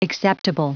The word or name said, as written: acceptable